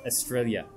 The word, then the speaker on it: Estrella: But, of course, those of us who speak Spanish would know that the double L is pronounced with a Y. So it's Estrella, which is kind of sounds a lot like Australia.